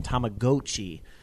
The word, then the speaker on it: Tamagotchi: And Akuma JP correcting my pronunciation, Tamagotchi.